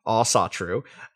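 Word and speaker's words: Ásatrú: Asaltru, pronounced Asatru, absolutely doesn't make sense as a term for the pre-Christian faith of Scandinavia at the time of Crusader Kings 3.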